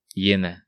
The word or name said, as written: Hiena